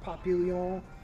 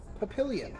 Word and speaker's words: Papillion: So it's not pronounced Papillon like it would Papillon, be in the French. – That's how it should be pronounced. – French for butterfly. – Mm. Yeah. Did not know that. – They pronounce it Papillion.